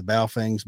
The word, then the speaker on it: Baofeng: Some of those things, like those, I'll probably mispronounce it, the Baofengs, Bofengs, you know, the radio. – Yeah, Baofeng, that's it.